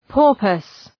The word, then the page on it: porpoise: ‘pɔ:rpəs